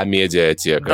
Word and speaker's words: Амедиатека: А, Ребят, давайте правильно говорить «А, «А-медиатека».